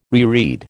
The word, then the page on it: reread: American English